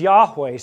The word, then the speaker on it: Yahweh: We generally pronounce it as Yahweh.